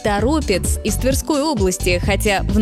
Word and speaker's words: Торопец: Поэтому ударение сохраняется в корне слова – Торопец. Интересно, что в названии городов, которые заканчиваются на «ец», часто действует другое правило. Ударение тянется ближе к концу слова. Елец, Череповец, Гороховец. Но Торопец – исключение, и именно поэтому он регулярно становится ловушкой для произношения. Такие примеры в языке встречаются часто. Название места как будто само подсказывает, как его правильно произносить. Нужно только увидеть его происхождение. И в этом случае все однозначно. Правильно говорить «Торопец».